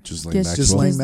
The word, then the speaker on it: Ghislaine: Ghislaine Maxwell.